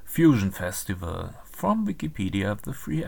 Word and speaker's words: Fusion: The festival name is often depicted in Cyrillic letters as Fusion, but pronounced like the English word fusion.